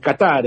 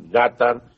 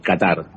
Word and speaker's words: Qatar: Porque no sé bien cómo decirlo eh, Qatar, entiendo en español. Qatar. en árabe, eh, sí, en árabe es con acento en la primera el Qatar, pero en español es, este, es Qatar, sí.